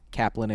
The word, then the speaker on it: Kaplan: Mike is spelled M-Y-Q. Kaplan is K-A-P-L-A-N.